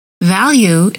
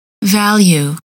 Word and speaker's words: value: Value is pronounced with the long U. Value.